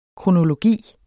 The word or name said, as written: kronologi